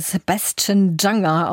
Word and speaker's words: Sebastian Junger: Sebastian Junge, aber es ist ein Amerikaner, also wird er Sebastian Junger ausgesprochen, nehme ich mal an, auch wenn es ein bisschen seltsam klingt.